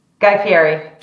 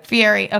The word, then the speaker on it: Fieri: But just okay, so coming out and minor segue, Just Be Kitchen just hosted Guy Ferrari. I actually with Diners, Dive-Ins and Drives, watch it, but do I say his name right? – Guy Fieri. – Fieri.